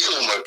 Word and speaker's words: Toomer: Frederick Lee Toomer, T-O-O-M-E-R, the last name.